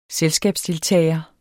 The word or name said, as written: selskabsdeltager